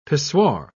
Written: pissoir

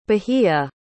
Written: bahia

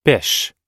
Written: bish

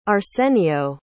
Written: arsenio

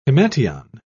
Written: himation

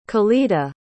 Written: khalida

